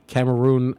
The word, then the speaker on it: Cameroon: So you came to the United States at the age of 10 from Cameroon, Africa.